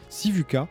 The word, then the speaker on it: Sivuca: Sivuca.